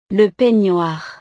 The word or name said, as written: le peignoir